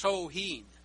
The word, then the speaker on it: Samhain: Now, the Druid God of the Dead is spelled S-A-M-H-A-I-N, which you would think would be pronounced Samhain, but it's pronounced Soheen,